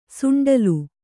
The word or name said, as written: ಸುಂಡಲು